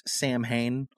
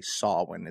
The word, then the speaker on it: Samhain: You've probably heard it pronounced Samhain. A lot of people will say that, but the word is actually Samhain.